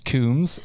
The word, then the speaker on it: Coombs: And I'm like, Jason Combs? And he's like, it's pronounced Coombs.